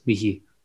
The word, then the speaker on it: Michi: You can call me Michi if you Michi. Really pronounce it Michi. – Yeah, with the German.